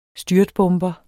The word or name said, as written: styrtbomber